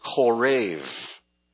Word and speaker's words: Horeb: Now, in Hebrew, this name, Horeb, is pronounced Korev. Korev. And the word Korev means basically to dry up.